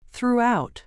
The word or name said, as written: throughout